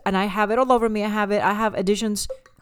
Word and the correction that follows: adhesions: That's how you say adhesions. – It? – Adhesions. – Additions. – Additions. Like subtractions. – How do you say that? – Adhesions.